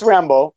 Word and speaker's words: Rimbaud: Oh, it's Rambo. It's Rambo, but Rimbaud.